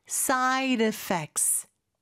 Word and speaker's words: side effects: Are, 다음에 there any, 그 다음에 side effects. 여기는 R 발음이 아니라 side의 D 발음이 모음소리 상에 껴서 리을처럼 부드럽게 발음이 됩니다. 그래서 side effects가 되는 거죠.